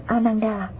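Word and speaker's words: Ānanda: Ngài Ananda, Ấn Độ đọc là Ananda, chữ Hán là Khánh Hỷ, là em, con chú của Phật.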